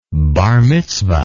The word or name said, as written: bar mitzvah